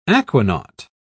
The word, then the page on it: aquanaut: British English